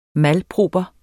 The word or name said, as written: malproper